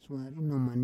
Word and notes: suari noman: Roon

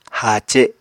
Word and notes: haace7: Apatani

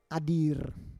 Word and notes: adir: Roon

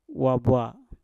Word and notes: wabwa: mpur

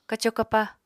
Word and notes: kaco kapaá: apatani

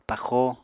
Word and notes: paxo: Apatani

